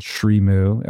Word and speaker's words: Srimu: Is that how you say it? – So Julie recently launched a plant-based cheese company called Srimu, S-R-I-M-U.